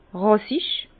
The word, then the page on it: ràssich: Bas-Rhin; Herrlisheim